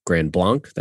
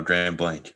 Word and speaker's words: Grand Blanc: You know, Ty Rogers from Grand Blanc. That's how you pronounce it, right? – No, Grand Blanc. – Grand Blanc. – Yes. – Gosh, darn it. I've outed myself as a non-Michigander. Yeah.